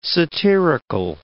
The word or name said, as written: satirical